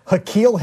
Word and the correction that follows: N'Keal: Harry? – Oh, N'Keal Harry. – N'Keal? N'Keal. – Yeah. – N'Keal in this case is spelled N'Keal. N'Keal.